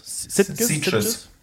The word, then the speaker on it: Sitges: Habt ihr die beide in Sitges gesehen? Wie spricht man das aus? – Sitges.